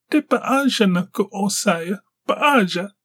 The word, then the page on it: Depazhana: Galindri